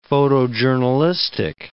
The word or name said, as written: photojournalistic